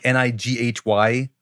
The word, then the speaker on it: Nighy: I think N I G H Y.